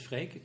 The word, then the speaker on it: Freek: And most English people have a little bit trouble pronouncing my first name right. In Holland, it's quite a common name. – Is it? – But yeah, it is Freek. It's a common name. In Germany... it's more like Frederick in that direction. But in England, the name is pronounced with an A sound. – Yeah. – And in America, it's getting an E sound. So okay. It's more like freak.